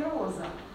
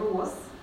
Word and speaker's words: роз: Поменяю форму, допустим, в этом году выпадало много роз, и, пожалуйста, на конце у нас произносится и слышна С, и, допустим, в букете много роз, то же самое, оглушается З, произносится как С.